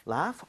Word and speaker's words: laugh: Laugh. – Okay. 자, 그러면 여기 써주세요. – Laugh at. 스펠링을 제대로 만들어주세요. – Laugh. 그 같은데요? – GH는 F와 비슷하게 발음이 됩니다.